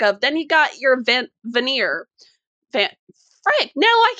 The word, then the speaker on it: Vanir: And I cannot tell you how much I was screaming at the screen when they kept talking about the Vanner, as they couldn't even say the name right.